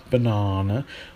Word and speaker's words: banana: I bought a banana. Banana. Students never understand how we pronounce this word. It's got schwa, then R, then schwa. Banana.